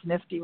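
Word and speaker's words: NFTE: You call it NIFTY. It's pronounced NIFTY, right? You say it NIFTY.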